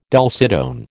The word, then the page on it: dulcitone: American English